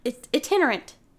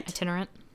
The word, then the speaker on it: itinerant: Am I saying that right? Iterant. Itinerant. – Itinerant.